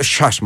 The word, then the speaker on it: ΣΥΑΣ: Η συνέχεια της ιστορίας με την Αθλητική Ένωση ΣΥΑΣ, γιατί μας είπα ότι προφέρεται και με παχύ. – ΣΥΑΣ ή ΣΥΑΣ. – ΣΥΑΣ. – Δεν ξέρω πού τονίζεται. – Είναι το ΣΥΑΣ, μου αρέσει.